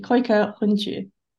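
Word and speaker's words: Kooikerhondje: The Nether Denali's Koika Hunchi Club of the USA breaks down the Koika Hunchi pronunciation. So you say effortlessly, Koika Hunchi.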